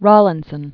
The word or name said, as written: Rawlinson